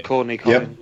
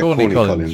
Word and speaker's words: Corny Collins: So they're going to the show and they're auditioning for the for a part on the Crazy Colin show. Is it Crazy Colin show? Have I said that wrong? – Yeah. Corny Colin. – Yep. Yeah, Corny Collins.